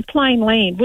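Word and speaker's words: Plainland: I always call it plain land, but it's actually plain land, which doesn't sound right. – There's no S.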